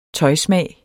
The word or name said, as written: tøjsmag